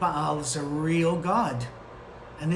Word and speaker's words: Baal: Or we thank Baal, or it's usually pronounced Baal, for the food that was sacrificed to him.